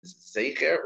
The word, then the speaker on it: Zecher: To repeat, the Iker reading of it is Zecher with a Zere. And therefore, either one you hear, you are Yotzi, but the Iker did, it's Zecher is the correct pronunciation of it.